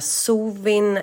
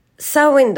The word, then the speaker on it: Samhain: Men jag ska säga också att är det någon som tycker att man ska säga sovin eller något liknande så behöver man inte skriva det till mig för jag eh, bryr mig inte om det. Och eh, Sawin är det keltiska uttalet.